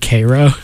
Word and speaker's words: Cairo: but they all pronounce it Cairo, and he was like. He talked to a guy at a gas station when he stopped for gas, and he was like, why is it pronounced Cairo? And the guy's like, because it's Cairo, Illinois. And he's like, you know, in Egypt, the one's called Cairo.